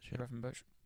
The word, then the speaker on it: Gravenberch: So it was a three of Szoboszlai, McAllister and Gravenberch, is that how you pronounce it? Gravenberch.